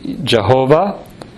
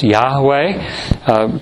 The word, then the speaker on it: Yahweh: That means it's the personal name of God, variously pronounced. It's pronounced Jehovah sometimes, and it's also pronounced Yahweh.